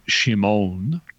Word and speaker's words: Shimon: You'd actually want to pronounce it like Shimon, but then he's the son of a man named John or Jonah.